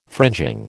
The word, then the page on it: fringing: American English